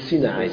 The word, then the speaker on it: Sinai: Del Sinai, perché gli ebrei dicono Sinai, non dicono Sinai.